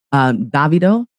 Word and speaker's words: Davido: I know he always says I say it wrong all the time. – Little bro. – Yeah. Our little bro who's Nigerian, he likes to correct me. And I'm like, well, I don't speak the tongue of Africa or Nigeria. I'm saying it the way that I read it, but he's another good producer and he's huge. He's huge. And what is his name? – Davido. Oh my God, let me spell it right. D-A-V-I-D-O.